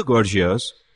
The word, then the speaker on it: Gorgias: Start quote, in the Gorgias, is that the right way to pronounce it? – That's, yes, good.